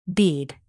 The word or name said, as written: bead